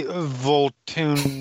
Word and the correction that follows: Van Tool: You've said his name incorrectly almost every time you've said it. – His name literally has Tool in it. – Van Tool. Not Voltaire.